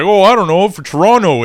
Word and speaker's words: Toronto: They don't pronounce the second T. It's silent. – Toronto.